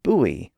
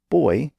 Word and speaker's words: buoy: and the word buoy, or buoy, depending on your pronunciation, for a floating marker in the water.